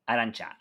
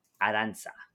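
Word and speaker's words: Arantxa: It's like in the same way as how do I say it? Arantha? – Arancha. Okay, Arantha? That's another one. That's another one where there's two different ways to say it. Arancha or Aranza.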